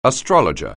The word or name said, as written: astrologer